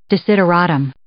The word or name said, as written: desideratum